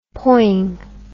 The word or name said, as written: poing